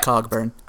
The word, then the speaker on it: Cogburn: I'm not sure how you want to pronounce it. Cogburn. – It's right. Cogburn.